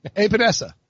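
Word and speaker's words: Epenesa: AJ, I can't ever say it right. How's say it again for me? – Read it. Sound it out. – Epinesa. – Yeah, that's good.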